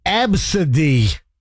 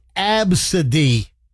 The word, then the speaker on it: Abcde: Number one is Absidy. And it is spelled ABCDE. And it's pronounced Absidy.